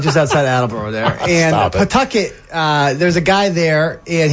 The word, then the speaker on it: Pawtucket: It's pronounced Pawtucket.